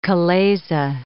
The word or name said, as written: chalaza